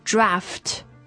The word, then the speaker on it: draught: Draft, 怎么拼？ – There's two ways to spell it. – 第一种。 – D-R-A-U-G-H-T. Or D-R-A-F-T.